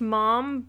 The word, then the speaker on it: ma'am: Yeah, it's not ma'am. It's still pronounced mom, but it's kind of more of the ma'am.